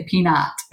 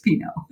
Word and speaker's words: Pinot: Well, it's kind of like Pinot, right? Yeah. A Pinot Noir. When somebody look at it, they'll say peanut. And I'll say, well, it's actually pronounced Pinot. Yeah. The T is silent.